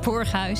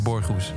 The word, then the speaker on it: Borghuis: Denk ik dat ze het in, eh, in Doetinchem zouden uitspreken, maar mocht je niet vanuit... netjes het Borghuis.